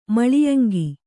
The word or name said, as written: ಮಾಲ್ಯಾಂಗ